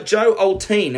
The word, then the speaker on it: Joe Oltean: All right, so we're here with uh, Joe Oltean.